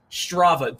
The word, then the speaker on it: Strava: Skava, what is your thing? – Strava. Don't you mispronounce it again.